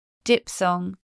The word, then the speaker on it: diphthong: PH spelling before th is pronounced per in 2 words. Diphthong.